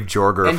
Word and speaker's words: Joerger: I'm a huge fan of Dave Joerger being their Dave head Yeager.